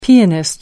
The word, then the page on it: pianist: American English